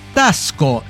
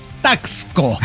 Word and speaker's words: Taxco: Así se pronuncia, Tasco. Usted no me va a venir a decir cómo tengo que pronunciar Tasco.